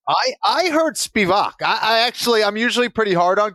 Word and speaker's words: Spivak: Sergey the polar bear Spivak. – Spivak. – Spivak. Spivak. Spivak. – I mean, I hear Spivak. You know, I actually, as long as you're emphasizing the first syllable, I don't really think it matters.